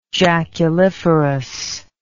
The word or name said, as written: jaculiferous